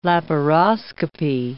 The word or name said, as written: laparoscopy